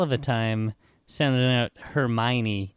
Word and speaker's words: Hermione: I think when you would buy Harry Potter, they would tell you it's pronounced Hermione.